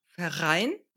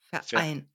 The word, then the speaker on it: Verein: Du hast es hier jetzt verbunden, Verein, aber in dem Präfix wird das R nicht mitgesprochen und wir verbinden die beiden Silben nicht. Sag das nochmal. – Verein. Verein, Verein.